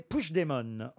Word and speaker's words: Puigdemont: Et on doit prononcer Puigdemont, en fait.